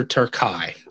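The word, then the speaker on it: Terkay: Sylvester Turkai, although it's spelled like Turkey. – Yeah, that's right. I was reading things and watching things, and I'm reading it as Turkey.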